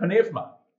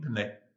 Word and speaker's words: pneuma: So they pronounce both the P and the N, pneuma. But a U is a V. So it's pneuma. So like the word eureka is actually evrika, not eureka. So just a little one there. If you're talking to a Greek, you've got to pronounce it pneuma, not pneuma.